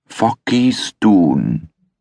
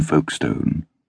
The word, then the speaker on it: Folkestone: "Focky Stoon." Her mispronunciation was like sarcasm, and she looked as unimpressed as Trollope's Lady Glencora. There was nothing she wanted so much as to see Folkestone.